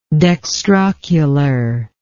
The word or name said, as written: dextrocular